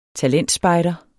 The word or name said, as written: talentspejder